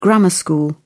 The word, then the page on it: grammar-school: British English